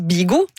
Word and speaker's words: Vigo: Han är från en by som skrivs Vigo men uttalas Bigo. – Ja. – Som ligger norr I Spania. – Ja, det var nog det första jag lärde I spansktimmen på ungdomsskolan var att V uttalas B I hvert fall I Spanien.